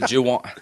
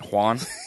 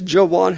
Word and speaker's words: Juwan: Let me say Juwan Howard again. Is it Juwan? Just say his name. – Juwan Howard. – Juwan Howard. Mm-hmm. What am I saying? Juan? – You even say it with like a Spanish accent. Juan? – That wasn't that.